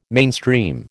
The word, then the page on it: mainstream: American English